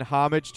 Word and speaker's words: homage: The brand says it's in homage to Miller High Life's upcoming... – Homage. It's a silent H.